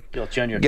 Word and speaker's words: Galchenyuk: Gal, uh, Galanchuk, how do you say his name again? – Galchenyuk. – Galchenyuk.